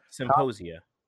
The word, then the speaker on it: symposia: I cannot really say that word. Symposium. I know I'm messing that up. – Symposia. – Did I say that? I said it right then.